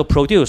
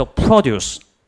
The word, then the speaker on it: produce: 梗系唔系啦，唔系读 produce 系读 produce，即系解农作物。哥伦比亚的农作物读 produce，所以有时唔同读音咧，轻重声唔同咧，系有唔同意思嘅。